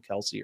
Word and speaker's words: Kelce: said that that his name's actually pronounced Kels, but he's never wanted to correct people because everyone's just called him Kelce or whatever.